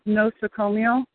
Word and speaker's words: nosocomial: I just wanted to say uh, it's pronounced nosocomial uh, infection that Sister Dee was trying to pronounce, and it means uh, hospital-borne infection.